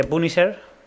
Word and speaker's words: Punisher: Siguiente, el segundo puesto se lo voy a dar a la recreativa de Punisher, que no sé si está bien pronunciado.